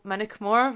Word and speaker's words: Moniack Mhor: Yeah, because you are in a wonderful place called Moniack Mhor, which you said that it's not widely incorrectly how I'm pronouncing it, so that's good.